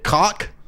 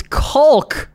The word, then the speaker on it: caulk: The construction shit, cock? – It's caulk. Caulk. – It's cock. – It's caulk. We've been over this. It's caulk. It's pronounced, isn't it? – The L is silent.